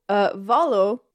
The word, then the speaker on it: Volo: So, Volo, which I'm finally going to pronounce correctly now that I know it's Latin, not Italian.